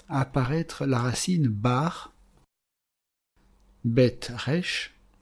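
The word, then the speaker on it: resh (ריש): Resh, aleph, shin, qui veut dire la tête, qui se prononce resh ou roche.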